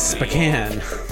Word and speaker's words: Spokane: Spokane.